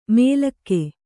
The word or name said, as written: ಮೇಲಕ್ಕೆ